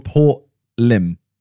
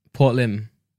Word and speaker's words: Lympne: It's called Port Lim. – Port Lim. – Yeah, but it's Lympne. – Okay. Well, thing is, you know, nowadays, when stuff's spelled like that, I'm like, why? Like, just spell it like L-I-M.